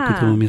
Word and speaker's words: Kuthoomi: Sí, mira, mi nombre es Kutumi, se pronuncia ah, pero Kutumi, está bien, no Kutumi, te preocupes.